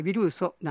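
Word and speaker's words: viruso: エスペラントには名詞、つまり物の名前は 50 音のオダンの音で終わるというルールがあるので、ウィルスじゃなくてヴィルソなんですね。また単語のアクセントは後ろから 2 番目の音節と決まっていますので、これもヴィルソの根拠です。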